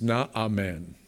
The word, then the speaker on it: Naaman: I'm going to call him Naaman. That's kind of the Gentile pronunciation. I looked up the Hebrew, and it's Naaman.